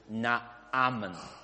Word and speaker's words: Naaman: If you want to read the name properly, Hebrew is Naaman. – 如果乃曼的名字按希伯来文我们好好的读的话，叫 Naaman. – That's why it's double A. Naaman.